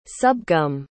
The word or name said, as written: subgum